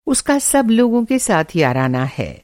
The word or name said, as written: याराना